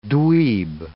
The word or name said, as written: dweeb